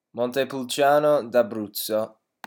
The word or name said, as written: montepulciano d'abruzzo